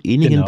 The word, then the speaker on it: Ehningen: Also Sie sind 1952 in Ehningen, wird das so richtig ausgesprochen? Ehningen, genau.